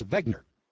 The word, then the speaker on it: Wegner: And my name is not Wagner, it's Wegner. Wegner. With a V. Wegner. But it's spelled with a W.